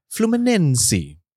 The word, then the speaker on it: Fluminense: It is pronounced as Fluminense. It sounds like Flu by Nancy, but never mind, I try my best, yeah?